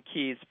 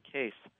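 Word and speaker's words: Kees: Actually, I called him Keyes for years, and then he was too polite to point out I was mispronouncing his name. But then I met a Dutch woman who informed me it's actually Case.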